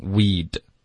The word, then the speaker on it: we'd: we would，我们就会把它用一个缩写的发音 we'd，然后 we'd 结尾的 t 其实也会省略，我们就发 we。